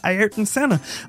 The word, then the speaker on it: Ayrton Senna: Ayrton Senna.